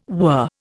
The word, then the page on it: whir: Indian